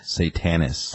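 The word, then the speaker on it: Satanis: It's S-A-T-A-N-I-S. Saintus? – Satanus. – Satanus, that's how you say it, okay.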